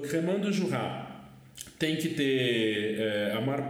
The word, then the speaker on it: Jura: Jura, se escreve, se diz Jurá, mas se escreve Jura, né?